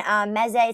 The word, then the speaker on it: mezze: They actually have Mediterranean mezze.